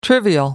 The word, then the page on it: trivial: American English